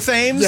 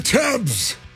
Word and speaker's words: Thames: T-H-A. M-E-S. – And I'm fully aware I pronounce that word like such an American. Like if I was over in London, I'd be like, hey, got that boat for the River Thames? – Yeah, that's right. Did you do that yesterday? – Yeah. – Okay, I think I wasn't really listening to you. – Well, I did pronounce it right-ish. I didn't say Thames. – Okay, yeah. – But I still don't feel like I say it right even when I say Thames.